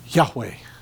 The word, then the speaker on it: Yahweh: YHWH. No vowels. Yahweh. That's how it's said in Hebrew.